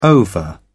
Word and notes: ova: British English

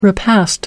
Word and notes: repast: American English

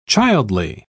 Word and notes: childly: British English

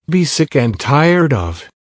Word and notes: be sick and tired of: American English

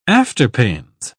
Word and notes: afterpains: British English

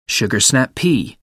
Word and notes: sugar snap pea: British English

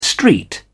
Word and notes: street: British English

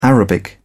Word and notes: arabic: British English

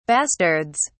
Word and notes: bastards: British English

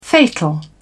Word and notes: fatal: British English

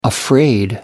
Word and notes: afraid: American English

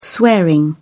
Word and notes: swearing: British English

